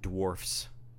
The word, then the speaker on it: Dwarfs: How are you pronouncing the last word? – Snow White and the Seven Dwarves. Dwarves. – No. – Dwarf. – It's with an F. – Dwarfs. – It's Seven Dwarfs.